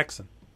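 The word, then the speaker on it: Häxan: Haxan.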